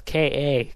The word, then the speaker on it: Ka: It's spelled K-A, Ka. Like Ka, you know? – I don't really care.